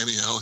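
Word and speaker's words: Anielle: Aelin and friends then go to Aniel. Am I saying that right? – I think so. – Yeah, Aniel, yeah.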